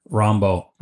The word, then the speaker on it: Rimbaud: His big influences are Dylan Thomas and Rimbaud. – Torn Down, Rambo. – Oh, I'm sorry. – I always pronounce it wrong too. – How do you say it? – Rambo. – Rambo. Thank you.